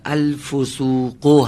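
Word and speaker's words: الفسوقُ: الفسوقو هست، درسته؟ ضمه آخرش هم شفاف و واضح تلفظ کنید که خراب نشه.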